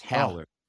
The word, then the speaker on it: Haller: Technically, no. It's Holler.